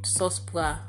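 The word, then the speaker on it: sòs pwa: Yeah, mamoule and Cespois. Cespois. I'm saying it right? – No. Sauce pois.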